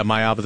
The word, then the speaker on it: Maiava: Maiava. – Yeah, Maiava, the USC quarterback.